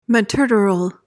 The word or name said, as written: materteral